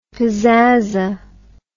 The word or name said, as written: pazzaza